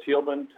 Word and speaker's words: Tieleman: My name is Bill Thielman. Last name is spelled T-I-E-L-E-M-A-N.